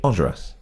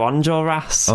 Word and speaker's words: Enjolras: And then, say it for me. – Enjolras. – There we go. I just can't do it. The northern in me just wants to go Enjolras. – Enjolras. Yeah, that's fine. – Really? – That'll do. Whatever. I mean, some people pronounce it Enjolras. – Enjolras. Okay, I quite like that. – But, you know, whichever.